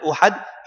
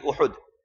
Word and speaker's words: Uhud: Many people pronounce it as Uhud, it is Uhud.